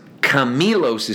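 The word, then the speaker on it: Camilos (κάμιλος): Not, I'm sorry, I mispronounced it. Camilos is camel, camelos is rope. They're one letter apart.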